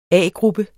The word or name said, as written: A-gruppe